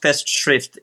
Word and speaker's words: Festschrift: Yeah, just Festschrift.